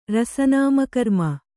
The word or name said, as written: ರಸನಾಮಕರ್ಮ